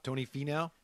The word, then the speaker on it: Finau: Finau? – Tony Finau.